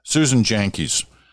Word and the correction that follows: Janke: It's not Janke. It's Jonky. All right. It's spelled Jan, but it's John. So Jonky. Susan Jonky.